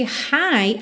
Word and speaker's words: high: I wanna be high. Lembra que esse high, H-I-G-H, tem exatamente a mesma pronúncia do hi, hello, somente H-I, ok?